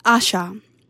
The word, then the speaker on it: Asa: Up next is the song 360 Degrees by Asha. Although her name is spelled as Asa, she is a young lady musician from Nigeria, and the song is of her first self-titled album, Asha.